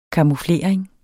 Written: camouflering

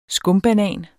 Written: skumbanan

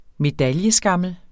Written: medaljeskammel

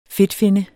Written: fedtfinne

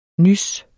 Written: Nyset

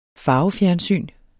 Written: farvefjernsyn

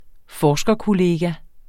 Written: forskerkollega

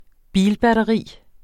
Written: bilbatteri

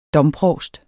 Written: domprovst

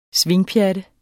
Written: swingpjatte

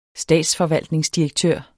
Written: statsforvaltningsdirektør

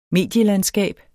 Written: medielandskab

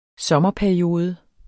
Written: sommerperiode

